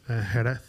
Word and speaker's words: Jerez: Jerez, which was like south of Seville.